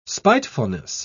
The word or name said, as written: spitefulness